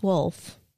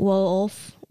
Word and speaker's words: Woolf: So I heard on a British podcast that it's actually pronounced Wolf. – Wolf? – Like Wolf.